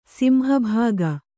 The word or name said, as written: ಸಿಂಹಭಾಗ